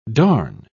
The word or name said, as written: darn